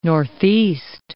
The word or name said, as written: northeast